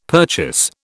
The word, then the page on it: purchase: British English